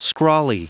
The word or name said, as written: scrawly